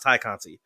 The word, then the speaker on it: Tay Conti: It's pronounced Tay Conti.